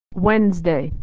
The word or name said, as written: Wednesday